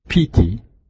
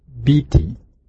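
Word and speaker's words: pīti: The Pali word is piti, and some pronunciations, biti.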